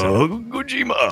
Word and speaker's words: Kojima: Is how you say Kojima.